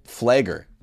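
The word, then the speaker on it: Pfleger: Think of Father Michael Flager, the P is silent, boys. It's a silent P.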